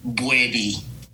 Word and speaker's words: Boadi: I just need you to get the pronunciation on my last name right. Boadi. – Boadi. – Let's do it. – Kwame, yes, Boadi. Better? – Yeah. Yeah. Boadi is the anglicized version, and I'm fully woke now.